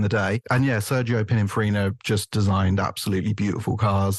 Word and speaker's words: Pininfarina: Pininfarina. – Pininfarina.